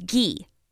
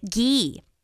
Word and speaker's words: Gee: And whenever I went to school, people would call me Lacey G. I'm like, no. It's Gee. You pronounce it Gee.